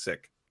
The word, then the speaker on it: Sikh: It's spelled S-I-K-H. – Sikh. – Some people say it's Sikh. It is Sikh, though, right? – So one of my best friends is Sikh. It's Sikh. Yeah, it's pronounced Sikh.